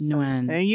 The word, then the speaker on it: Nguyen: It's pronounced Nguyen, like N-W-I-N. – So Nguyen.